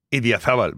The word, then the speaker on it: Idiazabal: Idiazabal.